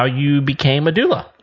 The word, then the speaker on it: doula: That provides doula services. Am I saying that right? – Yes, you are.